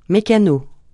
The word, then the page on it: mécano: francais